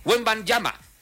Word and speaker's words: Wembanyama: Así que los Spurs todavía tienen vida gracias al enorme impacto de Víctor Wembanyama, quien ha convertido Wembanyama.